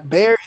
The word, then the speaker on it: Bexar: Bexar is spelled B-E-X-A-R. – All right. – That is Bexar. That is Bexar. And it's like Spanish. All right. It's not fucking Bexar, but white people call it Bexar County. And so San Antonio is officially in Bexar County despite it being spelled B-E-X-A-R.